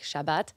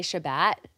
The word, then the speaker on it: Shabbat: So um, Shabbat would be in Hebrew like Shabbat, but Syrians say Shabbat with like a long A, like Shabbat Shalom.